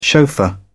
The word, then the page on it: chauffeur: British English